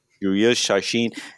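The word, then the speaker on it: Chacín: But and the other news of the day, with the Brewers announced this, formally announced the signings of Julius Chachin. Am I saying that right? Chachin? Julius Chachin and Giovanni Gallardo, who came up with the Brewer. – Julius Chachin.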